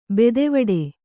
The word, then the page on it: ಬೆದೆವಡೆ: bedevaḍe